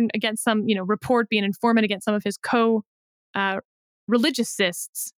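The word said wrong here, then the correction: coreligionists: How do you say that, David? – Co-religionists. – Religionists is the NF. – Religionists, yes.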